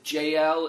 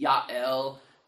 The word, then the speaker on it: Jael: Her name is, we pronounce it Jael in English, it'd be Yael, but she, Jael stands up, and what she does is she kind of tricks Sisera.